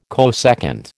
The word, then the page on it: cosecant: American English